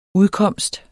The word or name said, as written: udkomst